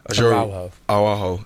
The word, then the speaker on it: Araujo: Firstly, Ronald Araujo, I can never say his name. – Araujo to Liverpool.